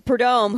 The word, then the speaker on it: Prudhomme: We see Don Schumacher Don the Snake Prudhomme. I knew I was saying that wrong. We see Prudhomme, who was Ron Capps' former team owner.